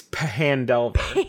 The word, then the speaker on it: Phandelver: How'd you pronounce it? – Phandelver? – That's Phandelver? – It's Phandelver. – Phandalin is the town, and then Phandelver is the mine.